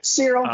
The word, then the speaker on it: Ciryl: Because that is the main event this week between Derek Lewis and Ciryl Gane, mm-hmm. if I'm pronouncing that right. – Ciryl, close enough.